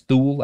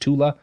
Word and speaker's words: Thule: which at that point is known as Thule. I think it's actually pronounced Tula.